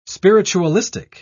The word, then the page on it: spiritualistic: British English